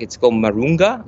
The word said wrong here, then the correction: moringa: leaves. So we're making like a moringa syrup. – Yeah, the moringa.